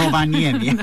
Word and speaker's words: Rovaniemi: Ați fost la Rovaniemi, nu? Am pronunțat bine, da? – La Rovaniemi. Da. – Rovaniemi, așa, da.